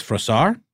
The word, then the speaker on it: Froissart: I believe it's Froissart. – Yeah, that's what we went with. – Yeah, that's right.